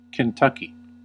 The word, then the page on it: Kentucky: English